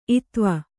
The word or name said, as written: ಇತ್ವ